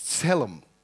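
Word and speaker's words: selam (צלם): It's pronounced with a t, so it's selam.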